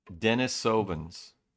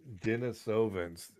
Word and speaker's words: Denisovans: How do you pronounce it? The Denis. You're gonna make me suffer with it, aren't you? – What? – Denisovans. Denisovans, and this is an article.